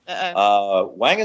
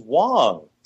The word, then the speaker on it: Wang: Wang is Wong, believe it or not. So I am so sorry. Garrett Wong. – It's pronouncing it the way that most 99% of Americans would pronounce it because it's spelled A, and Americans usually pronounce an A hard and not soft, right? – So it's Garrett Wong.